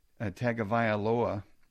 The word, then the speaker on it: Tagovailoa: Quarterback Tua Tagovailoa, I think I butchered that name, Tagovailoa.